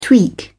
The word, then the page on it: tweak: British English